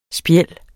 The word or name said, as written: spjæld